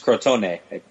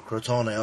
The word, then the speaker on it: Crotone: So even, actually, Victor has a piece on the Crotone game. Am I saying that right? – I think it's Crotone, I believe. – Oh, Crotone, okay, yeah.